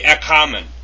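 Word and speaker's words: ekamen (ἔχομεν): which is ekomen, with a key, peace with God. Let's go back and do that again. we notice that in the first, it's actually ekamen. I mispronounced it.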